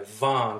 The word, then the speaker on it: Vaughan: I had to Google how to pronounce this city in Canada, Vaughan, because it has like G's and H's and W's in it.